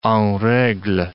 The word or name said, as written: en regle